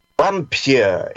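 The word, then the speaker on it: Wampyr: and he gave him the word Vampire. It would be pronounced vampire, but it's spelt with a W. W A M P Y R or I R.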